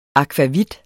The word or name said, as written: akvavit